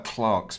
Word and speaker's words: Clerks: Clerks. That's the one. Oh, God. – You used right. to call that Clarks, by the way. I did, because that's how you pronounce it in England.